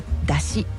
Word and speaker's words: 山車: みこしの行列や出汁、これ山車と書いて通常出汁と読ませるんですが、今日ご紹介するのは飲み神明宮の大祭なんですね。飲み神明宮では、地元の方たちは出汁